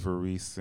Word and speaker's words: Variste: My name is Donald Varese, and Varese is spelled V-A-R-I-S-T-E.